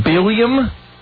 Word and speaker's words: Billiam: B-I-L-O-I-A-M. Biloian. – The consensus is Biloiam. – Hi, Biloiam? – It's Billiam. – Billiam? – Billiam. – Would you spell that for me, please? – B-I-L-L-I-A-M.